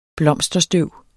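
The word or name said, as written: BLOMSTERSTØV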